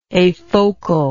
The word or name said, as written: afocal